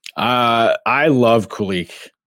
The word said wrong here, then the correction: Kulich: It's not Kulik. It's Kulik. – Kulik. – I got to make sure I don't butcher it. You kind of have to like get a little phlegmy like in the back of the back of the mouth. Like, Kulik.